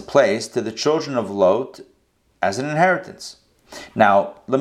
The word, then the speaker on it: Lot: I'll go Lot, because that's the Hebrew pronunciation is Lot.